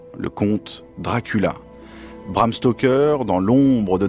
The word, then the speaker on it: Dracula: The sound is so intriguing. Three syllables. Dracula.